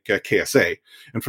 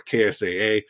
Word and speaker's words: KSA: But we also saw, you know, the impact, or at least maybe the potential impact of someone like KSA. And for those of you who are curious, well, isn't it KSA or KSAA? No, he actually shared on Twitter. He is KSA. That's how you pronounce it.